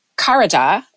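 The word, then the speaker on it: charada (חרדה): One is charada, and it's spelled C-H-A-R-A-D-A-H, charada. And the other is yirah, yirah, and it's spelled Y-I-R-A-H.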